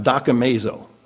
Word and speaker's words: dokimazo: In verse 28, this word examine that we read is spelled in the Greek, it's pronounced dokimazo, D-O-K-I-M-A-Z-O, dokimazo, examine yourself. The Bauer,